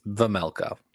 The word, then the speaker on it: Vejmelka: Uh, Vejmelka. Is that close? – Vejmelka. Yes. All right. Vejmelka. – Yeah. The J is basically silent.